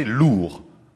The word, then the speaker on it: lures: Il porte sur le dos deux lures, prononcées lourds.